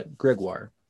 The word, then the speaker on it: Gregoire: Am I saying that right, Gregoire? – Yeah, that was pretty good. Yeah. – Okay, good. I wasn't sure if you'd say it Gregoire in the French, so, but Gregoire. Perfect.